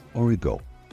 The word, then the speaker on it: origan: Oregano, or oregano, in French is pronounced origo and spelt O-R-I-G-A-N.